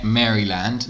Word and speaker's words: Maryland: Maryland. – How would an American say it? – Maryland. – You put emphasis on the L, but just the L.